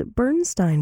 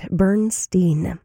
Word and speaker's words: Bernstein: Upon seeing the name Elmer Bernstein, you might be tempted to pronounce it as Bernstein because of the great Leonard Bernstein. However, they were alive during the same time, but Elmer, the way that he wanted his name to be pronounced was the opposite, Bernstein.